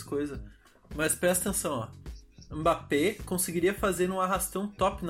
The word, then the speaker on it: Mbappé: Mbappé. – Mbappé.